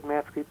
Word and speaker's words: Matsqui: You launched your book in a prison in the Matsqui, is that correct pronunciation? – Matsqui, yes.